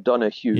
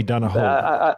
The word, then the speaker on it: Donahoe: It's spelled Donahoe, but I understand it's pronounced Donahue. – Yeah, yeah. Not Johnny Donahoe. Donahoe? – No, exactly.